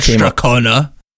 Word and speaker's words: Strathcona: Lord Strathcona.